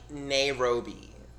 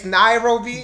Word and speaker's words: Nairobi: he was she was like oh, I want to go to like Nairobi. I was like, what? Nairobi? I was like, shouldn't you at least know how to pronounce the name of their capital before you go? It's Nairobi.